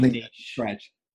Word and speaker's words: niche: And they would have said a niche, but it doesn't rhyme with rich. – True, niche. Yeah, true. You got to go for the amount. Yeah. If you're in Australia, we say niche.